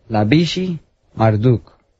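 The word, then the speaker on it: Labashi-Marduk: y le entregó el imperio a su hijo, quien era, en inglés se pronuncia Labishi Marduk.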